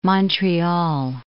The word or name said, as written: Montreal